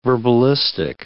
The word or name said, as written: verbalistic